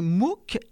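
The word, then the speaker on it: MOOKs: Les MOOCs, M-O-O-K-S, c'est comme ça qu'on prononce.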